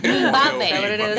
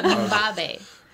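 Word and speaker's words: Mbube: It's spelled like M B O B E, but it's pronounced. It's not. – Mind your own business. – It's like Mumbabe. That's how you say it, Mumbabe.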